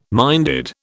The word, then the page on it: minded: British English